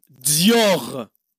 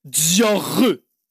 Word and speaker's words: Dior: Allez, brand number first, we got Dior. Dior. Dior. Not too bad, but it's more like R. Dior.